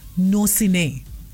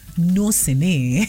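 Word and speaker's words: gnossienne: is called नोसिने जी द स्पेलिंग इज जी एन ओ एस एस आई ई एन एन ई नोसिने yes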